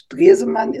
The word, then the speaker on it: Stresemann: In German, it's Stresemann. It's difficult to pronounce.